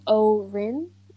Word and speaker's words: Haut-Rhin: Okay, so Keller was born on December 13th in France, and in this region called Haut-Rhin. I'm definitely butchering that, but it's like O is in like upper, like H-A-U-T.